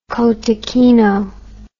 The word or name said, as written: cotechino